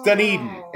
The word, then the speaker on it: Dunedin: No. It's Dunedin. – It's Dunedin. Just think like, hey, would you like to have another hot dog? No, thank you. I'm done eating. Dunedin. Yeah, that's how they pronounce it.